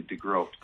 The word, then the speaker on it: DeGroot: The two O's are always deceiving, but it's actually DeGroat.